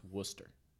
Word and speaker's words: Worcester: Worcester, the way that first part of the word is spelled, is actually pronounced Worcester.